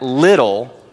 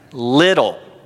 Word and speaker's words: Liddell: To help you remember how to say his family name, just remember that Liddell rhymes with fiddle. Repeat after me. Liddell. Come on, guys. Liddell.